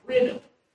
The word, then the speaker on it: krino: So the word judge is the Greek word krino. We would spell it K-R-I-N-O,